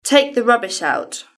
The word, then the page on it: take the rubbish out: British English